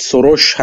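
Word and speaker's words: Soros: اسم درست تلفظ لهستانی درستش میخوام بگیم جورج سوروش هستش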